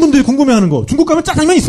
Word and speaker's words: 짜장면: 짜장 작장면을 중국 발음으로 읽으면 짜장면 이렇게 되잖아요.